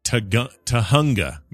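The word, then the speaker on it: Tujunga: The city you were talking about is pronounced... Tahunga. Remember that? – Tahunga. Yeah, we were calling it Tajunga. Tajunga man. – So it's Tahunga. Or Tahunga is how he says Tahunga. – It. Tahunga. Yeah, Tahunga. I grew up in Sundland, Tahunga area, and winced when you mispronounced it.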